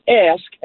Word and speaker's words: ask: When you say ask, A-S-K, you most times forget the K at the end to pronounce I do. – It. – Ask. – Now you're thinking about it. – You put a T like my mom.